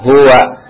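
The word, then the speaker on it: huwa (هو): Jadi kalau enggak tanda panjang bacanya pendek. Nah, kalau pendek kayak gimana? Ini lihat huwa beda enggak? Huwa. Iya, coba.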